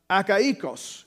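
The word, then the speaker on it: Achaicus: He says Stephanus, Fortunatos, and Achaikos is the way you actually pronounce that last name.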